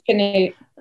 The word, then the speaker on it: Canute: Now, was Canute right? Am I saying that properly? – Yes, Canute.